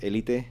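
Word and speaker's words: Élite: So it's actually pronounced Elite. – Elite. – The Elite. – Like, is there an accent mark? – Elite.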